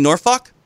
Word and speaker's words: Norfolk: I mean, are you comfortable saying Norfolk? – No, Norfolk. – But Norfolk. That's not how they say it.